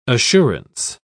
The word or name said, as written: assurance